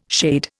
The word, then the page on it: shade: Indian